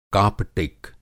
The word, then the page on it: कापटिक: Hindi